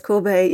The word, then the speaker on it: Courbet: I'm probably pronouncing him wrong. Is it Courbet? – Yeah, it's Courbet. Yeah.